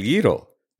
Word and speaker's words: gyro: gyro, el plato griego que se escribe G-Y-R-O, pero se pronuncia como si fuera Y-I-R-O.